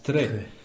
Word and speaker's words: tre: Qui s'écrit d'ailleurs T-R-E et qui se prononce « très ».